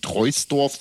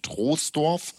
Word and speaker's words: Troisdorf: Das heißt, glaube ich, nicht Troisdorf, sondern tatsächlich Troisdorf.